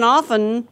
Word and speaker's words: often: You know, the T in often shouldn't be pronounced. – Although the last time I looked up Webster's, I mean, not the online, but the one with a binding and pages, they said often was okay.